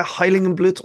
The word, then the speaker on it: Heiligenblut: Yeah, Heiligenblut or Heiligenblut.